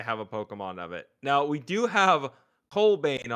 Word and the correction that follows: Cobalion: on... Cobalion? How do you say it? – Cobalion. Yeah.